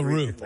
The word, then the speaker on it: Roof: How do you spell Roof? R-O-O-F? – R-O-O-F, exactly like the roof on a house. The first name is spelled D-Y-L-A-N-N.